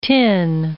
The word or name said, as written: tin